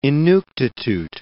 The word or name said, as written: Inuktitut